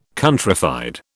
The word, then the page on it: countrified: British English